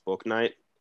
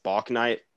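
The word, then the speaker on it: Bouknight: I like one of the acquisitions they got through the draft and James Booknight, which I keep saying Booknight, but apparently it's pronounced Book Night.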